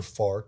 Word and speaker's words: Pharr: And a lot of people don't know where Far Texas is. And Far Texas is spelled P-H-A-R-R? – Yes.